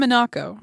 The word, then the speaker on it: Minako: I wrote down Minako.